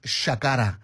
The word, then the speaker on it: Shakara: And of course, I needed to remember that in most cases in Yoruba, the S is pronounced as she. You might remember Felani Kulapukuti playing Shakara, Tubaboni Shakara Pade, Bashatoma Fila Holonje, Kineshe Hopio, Shakara Holonje, meaning the braggart. So, but it's written S, like you'd say it's written Sakara, but pronounced Shakara.